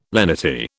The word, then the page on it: lenity: British English